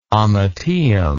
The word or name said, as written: ommateum